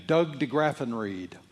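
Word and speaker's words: Doug De Graffenried: Doug DeGraffenried. I'm sorry, you'll have to spell that for me. D-E space capital G-R-A-F-F-E-N-R-I-E-D.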